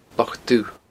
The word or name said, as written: Loch Dubh